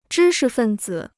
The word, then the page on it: 知识分子: zhī shi fèn zǐ